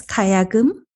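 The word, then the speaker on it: kayagum (가야금): Yes, kayagum.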